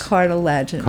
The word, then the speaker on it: cartilaginous: And how do you say cartilaginous? – Cartilaginous. – Cartilaginous. I have to accent on that.